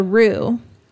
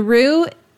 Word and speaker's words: Rhu: And one more place for coffee, I would recommend The Roo. The Roo, and that's spelled R-H-U, is a stylish bakery, cafe, and grocery offering Appalachian bites and pantry staples in Asheville.